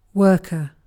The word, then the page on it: worker: British English